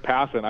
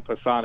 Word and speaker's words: Passan: And uh, Passon, is it Passan or Passon? I, I can't pronounce this. – It's Passon. – I hope if you ever have him on and you call him Passan, he'll immediately hang up and never do your show again.